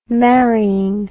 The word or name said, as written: marrying